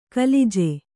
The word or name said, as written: ಕುಲಜ